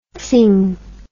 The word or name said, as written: tzing